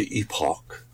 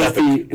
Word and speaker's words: epoch: Epoch is the British pronunciation. – Yes. – Yes. – And epic is the American.